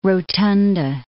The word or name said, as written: rotunda